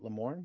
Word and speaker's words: Lamorne: is it Lamorne? Is that how you say his name? – Lamar? – L-A-M-O-R-N-E. – Lamorne? Lamorne? Lamorne? – Played by Lamorne Morris.